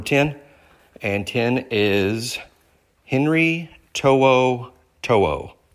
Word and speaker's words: To'o To'o: That's really how you say his name if you want to say it along with me in case you really want to learn how to say it, To'o To'o. And then when you look at his name, how it's spelled correctly, it makes sense that you pronounce like that, just To'o To'o.